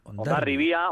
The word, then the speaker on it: Hondarribia: Hondarribia, Hondarribia. Naturalmente, la gente local lo pronunciamos así. – Vale. – Digamos, más hacia la comarca exterior, Hondarribi, pero bueno.